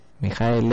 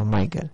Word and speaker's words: Michael: Mijael Ende, por cierto, no Michael. Que hay tanto pronunciar las cosas en inglés por la cosa que sé decir en alemán. voy de guay otra vez, como si yo supiera, ¿no? No tengo ni puta idea ni en inglés ni en alemán ni de nada de esto, pero bueno, sé que no se dice Michael, que se dice Mijael.